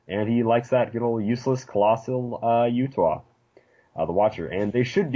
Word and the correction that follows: Uatu: It's Uatu.